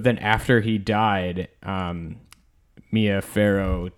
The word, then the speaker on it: Farrow: Mia Farrow. – Okay. – Like an Egyptian pharaoh. – Oh, gotcha. – Not spelled the same way, but pronounced the same way.